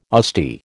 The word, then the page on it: Asti: American English